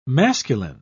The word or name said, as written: masculine